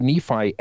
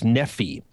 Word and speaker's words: Nephi: In ancient Egypt, there was a name that they didn't use vowels in Hebrew, and so it was spelled NFR, and by the time that the Lehites were fleeing Jerusalem, heading to the New World, the R sound would have been replaced by a long E sound, And so it would have been pronounced most likely as Nephi.